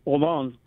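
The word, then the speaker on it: Haaland: It's her crony, the one that keeps selling as half Native American when her, you know, Deb Holland, it's pronounced Holland, and it's Norwegian,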